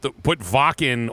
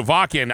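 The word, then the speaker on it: Wacken: I'm laughing because if you look at Vakken phonetically, it says, you know, Whacken. – It looks like Whacken.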